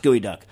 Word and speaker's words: geoduck: Yes, spell geoduck. Pronounce gooey duck.